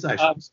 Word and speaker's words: Isham: Saying his last name wrong. I'm always worried about that. Isham. – It's Isham.